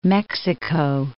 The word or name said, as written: Mexico